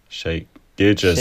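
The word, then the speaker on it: Shai Gilgeous: Shay Gillis. Wie wird denn der ausgesprochen? – Shay Gilgeous.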